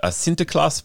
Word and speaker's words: Sinterklaas: You open the book, it's a Sinterklaas party, I hope I've pronounced that correctly.